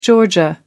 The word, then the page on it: georgia: American English